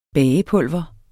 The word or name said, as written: bagepulver